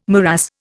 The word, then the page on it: morass: Indian